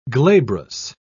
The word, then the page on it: glabrous: British English